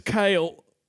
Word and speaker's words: Kael: He says, my name is Kale. K-A-E-L, pronounced like Kale.